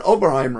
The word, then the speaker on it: Oberheim: I said Oberheim, right? – Yay! Yeah, said a you, you, You, yeah, you said it right.